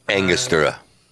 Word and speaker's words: Angostura: Angostura.